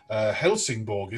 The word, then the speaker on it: Helsingborg: Your early jobs, Turku Philharmonic Orchestra for 3 years, and then Helsingborg, is that how you pronounce it, in Sweden? – Yeah.